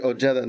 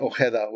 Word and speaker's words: Ojeda: And he's specifically Ojeda, not Ojeda, which is the way that you wouldn't pronounce it in Spanish, which right.